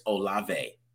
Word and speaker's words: Olave: I want to make sure I keep saying that right because I say Olive sometimes. Chris Olave.